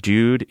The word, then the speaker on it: dude: He says that he hears from a friend of his that dude is pronounced to rhyme with nude, N U D E.